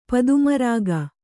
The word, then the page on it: ಪದ್ಮರಾಗ: Kannada